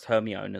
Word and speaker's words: Hermione: Yeah, and Mike, I think it's pronounced Hermione as well, by the way.